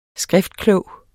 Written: skriftklog

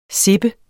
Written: sippe

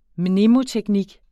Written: mnemoteknik

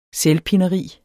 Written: selvpineri

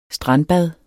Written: strandbad

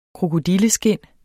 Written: krokodilleskind

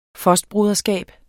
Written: fostbroderskab

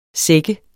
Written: sække

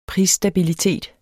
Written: prisstabilitet

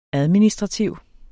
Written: administrativ

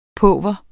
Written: pauver

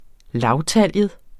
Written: lavtaljet

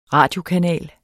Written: RADIOKANAL

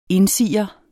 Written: indsiger

